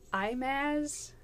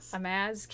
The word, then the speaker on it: Himaaz: So in 1872, Joseph Warren King and his nephew, Imaz, here's the thing, it's spelled H-I-M-A-A-Z, and I don't know how to pronounce that. – Imaz King, either way.